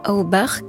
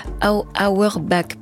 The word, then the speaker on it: Auerbach: Évidemment, tout ça c'est produit par Dan Auerbach. Voilà. Auerbach, pardon. J'ai vraiment du mal ce soir avec les prononciations, mais je vais m'en sortir, je vous le promets.